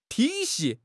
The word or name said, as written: 体系